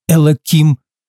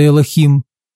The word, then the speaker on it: Элохим: Но поверьте, это нисколько меня не волнует, потому что многие иудеи намеренно неправильно произносят божественное имя Господа, называя его Элоким вместо Элохим или Адашем вместо Адонай, чтобы избежать использования святого имени Божьего в суе.